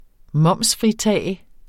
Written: momsfritage

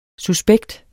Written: suspekt

